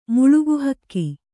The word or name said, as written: ಮಾಲ್ಕೀಹಕ್ಕು